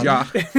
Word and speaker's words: Jacques: It's definitely Jacques. – Yeah, I think Jacques. The S's we can say pretty safely is not pronounced.